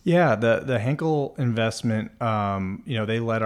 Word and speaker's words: Henkel: I tried to amass, didn't get into the round, but you guys now have announced a Series A with, I think, Henkel, or I don't know if I'm saying that right. – Henkel. – Which is a much bigger soap company.